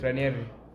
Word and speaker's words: Ranieri: Ranieri.